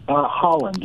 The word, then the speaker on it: Haaland: Haaland, like the country.